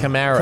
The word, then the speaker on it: Kamara: Is it Kamara or Kamara? – Kamara. – Kamara. He says, say it like Camaro, but hey, Kamara.